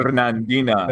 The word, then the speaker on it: Fernandina: I am not pronouncing Fernandina.